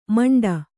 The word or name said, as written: ಮಿಂಡಿ